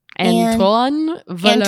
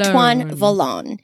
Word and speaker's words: Antoine Vollon: How do I pronounce that? An, Antoine Vollon. Antoine Vollon. Vollon.